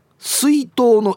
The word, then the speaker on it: 水筒: 水筒は水筒の「い」にアクセント